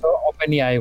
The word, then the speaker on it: OpenAI: Es director general de SpaceX, de Tesla Motors y presidente de Solar City, copresidente de la OpenIA. No sé cómo se pronuncia eso. – Eh, de hecho, es OpenAI, güey. O sea, como inteligencia artificial.